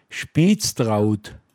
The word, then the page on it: Penggl: Pinzgauer Mundart